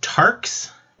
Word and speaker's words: Tiarks: Technically, it's just Tiarks.